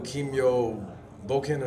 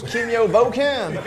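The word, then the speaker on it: Kimyō Bōken: Do you know JoJo's no Kimyo Boken or something like that? – Yeah. I mean, did I say it wrong? – It's just, you said it with like a total American accent.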